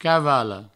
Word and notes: cavala: provençal; cavalo

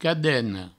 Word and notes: cadena: cadeno

